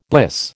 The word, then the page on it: bliss: American English